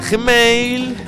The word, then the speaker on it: Gmail: Que se escribe, se dice Gmail. – Pero se escribe Gmail. – Muy bien. – Con una, no hay tantas as como parece. – Con una abierta o cerrada. – Da igual, en español solo hay una.